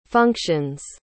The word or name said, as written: functions